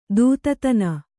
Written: ದೂತತನ

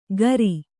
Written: ಗರಿ

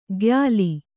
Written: ಖಯ್ಯಾಲಿ

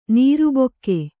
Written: ನೀರುಬೊಕ್ಕೆ

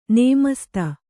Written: ನಮಸ್ತೇ

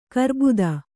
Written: ಗರ್ಬಿತ

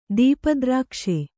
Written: ದೀಪದ್ರಾಕ್ಷೆ

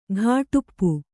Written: ಘಾಟುಪ್ಪು

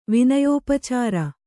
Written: ವಿನಯೋಪಚಾರ

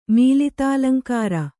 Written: ಮೀಲಿತಾಲಂಕಾರ

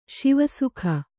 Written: ಶಿವಸುಖ

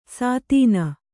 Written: ಸೀತೆನೆ

